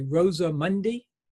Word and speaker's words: Rosa Mundi: Rosa Mundi? – Yeah.